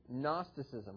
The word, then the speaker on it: Gnosticism: Now, that's interesting because the Greek word is spelled G-N-O, and it's brought over into English when we use the word Gnosticism, right? You notice that Gnosticism was spelled Gnosticism. That's because it's brought over from Greek.